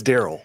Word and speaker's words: Darrell: Darrell Henderson, who I mispronounce every time. – Darrell? – I don't know. – I'm pretty sure it's Daryl. – Okay, I've been saying it right.